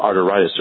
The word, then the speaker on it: arteritis: Is that how you pronounce it? The thing that's called GCA. Is it arteritis? – Good morning. Yes, it's called giant cell arteritis